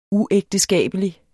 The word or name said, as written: uægteskabelig